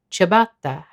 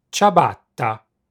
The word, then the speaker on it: ciabatta: In fact, the only relevant difference is that English speakers don't pronounce the double T. Ciabatta. Ciabatta.